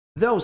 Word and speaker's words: though: Though significa no entanto. No entanto, tá? Though. Pronúncia though. Though. Um som de TH.